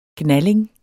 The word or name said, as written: gnalling